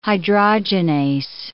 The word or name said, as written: hydrogenase